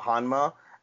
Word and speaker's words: Honma: Hanma, H-O-N-M-A, even though it's spelled H-A-N-H-A-M-N-A for this show.